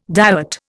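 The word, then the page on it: dévot: Indian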